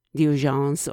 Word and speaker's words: Diogenes: Now, in this episode, he uses a personality very well known in Greek philosophy, Diogenes or Diogene, as in different languages the name is pronounced.